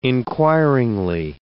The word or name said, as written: inquiringly